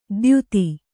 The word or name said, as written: ದ್ಯುತಿ